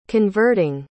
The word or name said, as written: converting